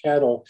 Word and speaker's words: CATL: The largest Chinese manufacturer, CATL, often pronounced cattle, is coming to the U.S.